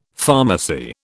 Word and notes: pharmacy: British English

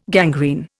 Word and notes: gangrene: Indian

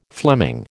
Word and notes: Fleming: American English

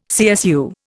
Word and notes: CSU: Indian

